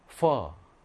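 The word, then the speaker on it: phở: ph는 영어의 f 발음이에요. f 발음. 자, fim. 자, 이거 우리가 아는 거야. 여러분들이 좋아하는 음식이야. 뭘까요? 퍼.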